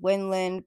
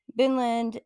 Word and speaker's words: Vinland: So Vinland, also called Winland, but pronounced from the Vikings as Vinland, is an area we now know of as North America, more specifically along the coast of Canada.